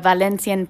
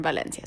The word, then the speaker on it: Valencia: And oh, when you go there, make sure you pronounce it Valencia, not Valencia. – Yeah, that's the Spanish accent.